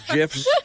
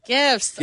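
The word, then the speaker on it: GIF: you're all over the place with GIFs, right? – GIFs. – GIFs, sorry, GIFs. – For the last time, hard G for me.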